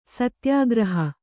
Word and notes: ಸತ್ಯಾಗ್ರಹಿ: satyāgrahi

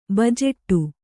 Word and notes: ಬಜೆಟ್ಟು: bajeṭṭu

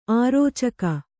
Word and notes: ಅರೋಚಕಿ: arōcaki

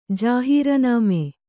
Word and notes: ಜಾಹೀರನಾಮೆ: jāhīranāme

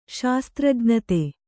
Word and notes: ಶಾಸ್ತ್ರಜ್ಞತೆ: kannada

